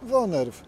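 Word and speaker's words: woonerf: Mówimy wonerw, wunerw, z urzędników różnie przypadają określenia. – Było sprawdzone. Słowo jest konkretne, pochodzi z języka niderlandzkiego i prawidłowo je się wymawia jako wonerw.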